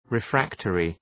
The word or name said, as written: refractory